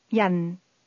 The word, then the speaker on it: 人: This word is pronounced with a falling tone. Say person. – Yan.